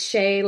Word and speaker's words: Shea: Now I just tell people that it's Shay like the butter, which tells you how to pronounce it and how to spell it.